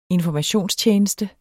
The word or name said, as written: informationstjeneste